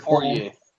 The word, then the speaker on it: Poirier: Poirier.